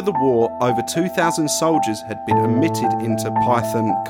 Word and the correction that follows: Pythian: Castle. Pythian. Pythian Castle!